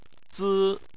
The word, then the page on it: zi: 1